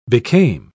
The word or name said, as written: became